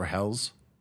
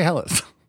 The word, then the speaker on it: Helles: Can we agree once and for all if we're going to say Helles or Hells? – I like Hells. – Let me rephrase. Should we pronounce it correctly or the way you want to say it? – We should say Helles.